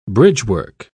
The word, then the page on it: bridgework: British English